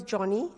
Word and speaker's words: Joni: Her name is pronounced as Johnny, as it's spelled as J-O-N-I, because that was given to her by her father.